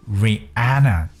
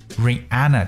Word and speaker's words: Rihanna: And her name is Rihanna，就是蕾哈娜。说到这位歌手，各位特别注意她的名字，在读的时候呢，叫 Rihanna。 Rihanna 这个 R I H A N N A 这个 H 是不发音的， Rihanna，大家有没有发现到？因为最近我们经常去听到华为的消息，有很多外国人在读华为的时候会读成华为，华为没有读华为，为什么？